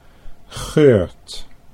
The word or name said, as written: Geurt